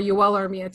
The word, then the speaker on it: Armia: And Jake Evans and Joel Armia are both, or Joel Armia to pronounce it correctly, are both that kind of guy.